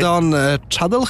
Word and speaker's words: Don Cheadle: Jackson mi się gdzieś tam zakotował, ale Don Cheadle, chyba tak się wymawia poprawnie to nazwisko.